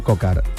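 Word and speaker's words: Coquard: Kokuar, seguramente no se dice así, pido disculpas, el ciclista francés. – Brian Cocar, que es precisamente el que está hablando, que imagino que no sé si se le entiende o no.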